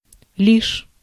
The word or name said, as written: but